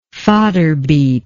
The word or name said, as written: fodder beet